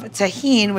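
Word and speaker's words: Tajín: And then we are going to use a little bit of tahine, which is one of my favorite spices. And you can buy it in the store, and it's spelled T-A-J-I-N. So when you're looking for it, however you may think you spell it is not right. This is how you spell it.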